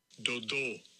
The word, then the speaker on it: Dodo: Dodo. – That's the pronunciation of that on my app for that guy's name.